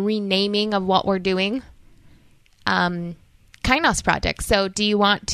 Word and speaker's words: Kainos: And yes, it's pronounced kainos. I've had a lot of people like, how do you even say that word? So it's right. Greek. It's kainos.